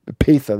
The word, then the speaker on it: Epatha: No, not Lieutenant, not the Epathia. – LT. – Epatha, that's how you say it.